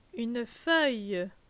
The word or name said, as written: Une feuille